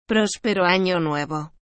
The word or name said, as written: prospero ano nuevo